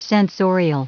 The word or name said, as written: censorial